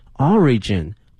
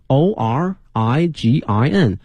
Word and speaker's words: origin: 它的名词呢是 origin， O R I G I N，表示起源。那么这个词呢，它的拼写不是很难，但是呢容易出现错误的呢是它的这个发音。很多同学啊会容易呢把它的重音放到第二个音阶上哈，它的重音应该在第一个音阶上， origin， origin。